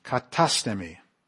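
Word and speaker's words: katastemi (καθίστημι): It's katastemi. Katastemi. The accent on the A.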